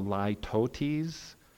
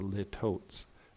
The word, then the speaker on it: litotes: this figure of speech is called the litotes, to pronounce it correctly. I often was told that this is a litotes. That's not how you pronounce it.